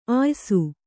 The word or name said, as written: ಆಯಸ್ಸು